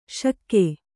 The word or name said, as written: ಷಕ್ಕು